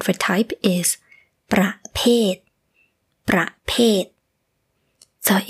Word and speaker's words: ประเภท: this word pronunciation is a little similar to ประเทศ which means country But it is different. For the word type, it is ประเภท. The second syllable is p, p, with the p, ประเภท. But for the country, it is ประเทศ. The second syllable starts with the sound like t in English.